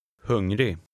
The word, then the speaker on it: hungrig: The final G is not pronounced. Read number seven. – Läs nummer sju. – Hungrig.